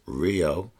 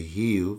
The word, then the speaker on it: Rio: And very almost like singing-like, where people from Rio, because it's not pronounced Rio, we say Rio, they say Rio, because the R is silent.